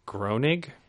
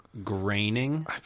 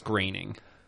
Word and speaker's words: Groening: Created by Matt Groening. – It's pronounced Groening.